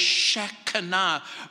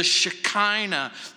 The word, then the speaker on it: Shekinah: Others suggest that this is the awesome presence of what's called the Shekinah, or mispronounced, the Shekinah,